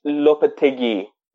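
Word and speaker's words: Lopetegui: این مربشون خولیان لوپتگی اولا این تلفظش چجوریه؟ – لوپتگی یعنی لوپتگی آره این چجوریه مثل که خیلی هم سابقه داره تو اسپانیا نه؟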